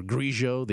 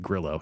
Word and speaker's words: Grillo: Emiliano Grillo, by the way, I don't know if we're pronouncing it Grillo, Grillo, or Grigio, the Argentinian pronunciation. Emiliano himself says for Americans to just pronounce it Grillo.